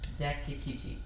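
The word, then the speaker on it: zacatechichi: And then the next word is Z-A-C-A-T-E. C-H-I-C-H-I-G-G. – Okay. – Zakachichi.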